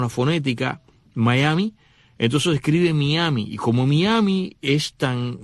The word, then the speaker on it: Mayaimi: Miami, que se escribe M-A-Y-A-I-M-I, Miami, Miami.